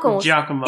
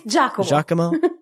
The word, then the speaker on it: Giacomo: I never know how to pronounce that. – Giacomo. It's Giacomo.